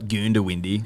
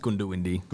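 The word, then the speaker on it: Goondiwindi: Yeah, but it's not Goondawindi. – Yeah. – It's Goondawindi.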